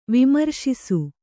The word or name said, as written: ವಿಮರ್ಶಿಸು